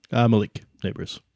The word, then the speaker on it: Nabers: This is kind of like this fella, what he has here, a guy named Malik Nabers. How'd I do there? – Malik Nabers. Pretty close.